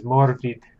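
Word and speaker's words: Morfydd: If you want to say it in the true Gaelic pronunciation, her name is Morvid, which sounds like a Lord of the Rings name to begin with. – Morvid. – Yeah, that's literally how you pronounce her name.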